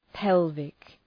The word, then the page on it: pelvic: ‘pelvık